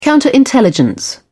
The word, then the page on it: counterintelligence: British English